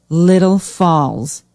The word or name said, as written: Little Falls